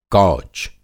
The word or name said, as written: कौच